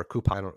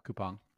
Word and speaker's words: Coupang: Portfolio is Ally Financial, Coupang or Coupon. I've heard Coupang. It pronounced both ways. – Yep, Coupang.